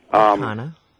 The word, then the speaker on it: Cana: Yesterday I had one, I still can't pronounce it, C-A-N-A, it's a girl. Mm-hmm. I don't know if it's Canna or Cana. – Canna.